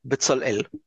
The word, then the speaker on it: Bezalel: So since you mentioned Smotrich, and I don't want to butcher his name, but Bezalel.